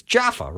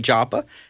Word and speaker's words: Jaffa: You know, people reading their Bible, you have a, you know, a name people are familiar with, like Joppa. – Yeah. – You're not wrong. But on the street, you know, if you're in Israel today, you hear the pronunciation just a little bit different.